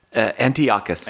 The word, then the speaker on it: Antiochus: Is it pronounced Antiochus or Antiochus? – Antiochus. – Antiochus, okay.